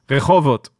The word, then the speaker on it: Rehovot: She was born in Rehovot. Say that properly for me. Rehovot.